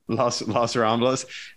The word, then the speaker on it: Las Ramblas: Las Ramblas? Is that how you say it? – Las Ramblas.